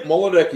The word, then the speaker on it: Moldecki: Yeah, Moldecki.